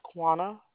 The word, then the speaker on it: Quanna: My name is Quana. – Spell that for me. – Q-U-A-N-N-A. – U-A-N-N-A.